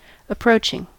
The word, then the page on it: approaching: American English